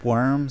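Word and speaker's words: Wvrm: That was Worm, spelled with a V, W-V-R-M, off of Heartache.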